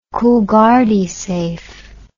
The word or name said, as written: coolgardie safe